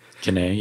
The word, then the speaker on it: Jhené: Jhené, yeah.